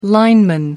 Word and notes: lineman: American English

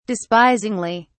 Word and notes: despisingly: British English

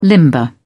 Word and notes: limber up: British English

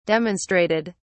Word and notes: demonstrated: British English

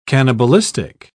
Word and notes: cannibalistic: British English

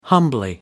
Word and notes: humbly: American English

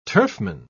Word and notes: turfman: British English

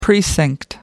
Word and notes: precinct: American English

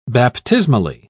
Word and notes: baptismally: British English

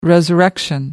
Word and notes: resurrection: American English

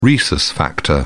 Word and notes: rhesus factor: British English

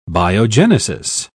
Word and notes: biogenesis: British English

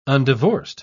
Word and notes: undivorced: British English

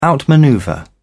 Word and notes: outmanoeuvre: British English

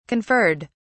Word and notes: conferred: British English

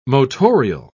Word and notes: motorial: British English

